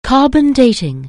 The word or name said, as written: carbon dating